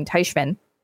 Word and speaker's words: Teichman: I never noticed until now that it's like kind of similar to the pronunciation of my last name, Teichman.